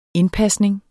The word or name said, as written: indpasning